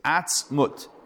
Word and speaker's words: atzmut: Okay, that's the first phrase, atzmut. It's one word, I'm saying it like 2 words, but it's really 2 syllables.